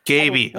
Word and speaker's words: Gabey: I feel I feel obliged to correct you, even though that's like super annoying that it's actually pronounced Gabey. – Gabey.